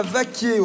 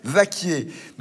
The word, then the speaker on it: Vacquiers: Dernier jour à Vaquier. Au fait, Vaquier, ça s'écrit V-A-C-Q-U-I-E-R-S. Vaquier.